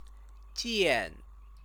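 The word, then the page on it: 件: jiàn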